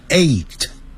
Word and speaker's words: eight: 要把上牙齿咬的下嘴唇发 v 的音 seven seven，八 e i g h t，那个好念 g h 不发音，念成 eight eight eight，九呢，因为是 n i n e，所以前面有个 n 就要念成 n。